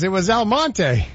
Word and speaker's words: Almonte: I figured out what Elmont was. Exclamation point, exclamation point. – Elmonte? – It was Elmonte. – Really? – Oh. Yeah. So it's spelled Almonte.